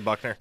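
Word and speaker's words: Buchner: Is it Tyler Buckner is how you pronounce that? – Yeah, Tyler Buckner.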